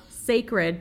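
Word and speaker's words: Cécred: Hair is Sacred, and the brand is called Sacred, but it's spelled C-E-C-R-E-D.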